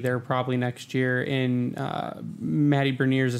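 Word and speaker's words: Matty Beniers: You got to say it right. It's Matty Berniers.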